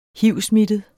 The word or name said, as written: hivsmittet